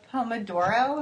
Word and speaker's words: Pomodoro: you wanted to talk about the Pomodoro. Am I saying that right? Technique. – Yeah.